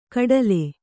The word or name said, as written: ಖಟ್ಲೆ